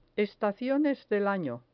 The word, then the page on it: estaciones del año: spanish